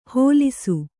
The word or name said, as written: ಹೊಲಿಸು